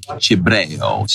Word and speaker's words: Cibreo: Cibreo, C-I-B-R-E-O.